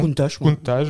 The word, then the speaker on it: Countach: Countach, voilà.